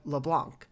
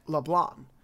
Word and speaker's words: Leblanc: So I asked my professor, who is from France, how you say that name, and he said that based on American English, you would pronounce the C so that it would sound like LeBlanc. But if you pronounce it in French, the C is silent, so it is more like LeBlanc.